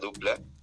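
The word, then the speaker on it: Doopla: Escribe con doble O, Dopla, pero se pronuncia Dupla.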